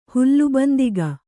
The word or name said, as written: ಹುಲ್ಲುಬಂದಿಗ